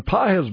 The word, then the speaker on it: Pages: Páez, by the way, I could see where that could be mispronounced because it's spelled P-A-G-E-S, like right. Pages. Mm. Páez, mm-hmm.